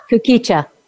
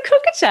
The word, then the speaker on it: Kukicha: This one makes me giggle because it's called Kukicha tea. – Kukicha. – Have you heard of that? You say it Kukicha? – Kukicha. – Oh no, I've always said a cucaracha. I was doing it wrong.